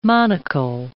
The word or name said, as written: monachal